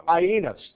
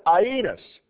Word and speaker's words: Aeneas: The first one we're going to talk about is a man named Aeneas. That is how you pronounce that name. It looks like, well, I won't say what it looks like, but we'll call it Aeneas. And that's how you pronounce it.